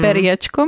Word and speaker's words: Periečko: Já tam mám problém trošku s tou výslovností, ale moderovala to, bylo to něco jako Peříčko, moderovala to Zuza Belohorcová. – Mm-hmm. Ale tam moderovala i Peříčko, myslím. – Mm. Periečko, myslíš? – Tak.